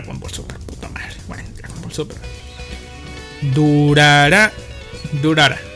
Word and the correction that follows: Durarara: Durarara, un anime basado en la novela ligera de Narita Ryogo.